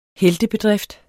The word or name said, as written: heltebedrift